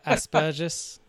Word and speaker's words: Asperger's: The word Asperger's, and I forget how you guys pronounce it. Very funny. – We say the same. Yeah. – I Yeah, no, you guys pronounce it. – Asperges, we say.